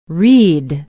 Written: reid

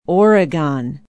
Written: oregon

